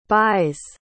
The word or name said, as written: bise